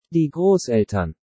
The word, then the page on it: Die Grosseltern: German